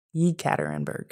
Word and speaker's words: Yekaterinburg: And then I flew to Yekaterinburg.